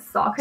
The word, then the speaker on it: Saucony: I don't know how do you pronounce it. – I pronounce it Saucony. – Saucony. Okay, I think a lot of Americans pronounce it Saucony. Um, but I, I've started to say Saucony.